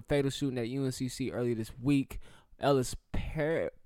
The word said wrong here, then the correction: Parlier: I want to make sure I say this right. Parlier. Can you say that? – Ellis Parlier and Riley Howell were two victims in that shooting.